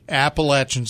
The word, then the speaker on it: Appalachia: I Googled, how do you pronounce it? And it's Appalachia.